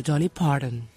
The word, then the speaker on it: Dolly Parton: No, señor, Dolly Parton, y usted sabe.